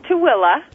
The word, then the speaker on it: Tooele: Am I pronouncing it correctly? Tule Valley? – It's Tooele. – Tooele. pronounce it Thule. That's what it's spelled.